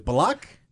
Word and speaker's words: Bullock: Yes, it's Bullock. It is not Bullock. It is Bullock.